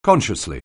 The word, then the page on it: consciously: anglais